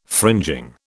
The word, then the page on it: fringing: British English